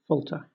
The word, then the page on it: falter: British English